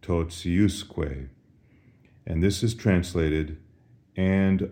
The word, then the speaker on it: totiusque: Notice the accent shifts from the T-I syllable to the U-S syllable. And as a result, the pronunciation of T-I is no longer T as in totius, but rather it softens. We have an S in between the T and the I, and so it sounds like this, totiusque.